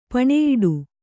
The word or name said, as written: ಪಣೆಯಿಡು